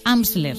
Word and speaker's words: Amsler: De Amsler, sí, así lo he dicho bien.